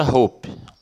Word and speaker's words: hope: Outro exemplo é a palavra hope. Hope. Hope, é mesma coisa. O E do final não é pronunciado, né? Não, é, não tem som, sai só ar, mas não sai vibração na garganta.